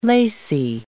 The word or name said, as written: lacy